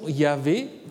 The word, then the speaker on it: Yahvé: Il y a des indications pour la prononciation Yahvé qui se trouvent surtout chez des pères l'Église.